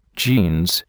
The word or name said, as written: jeans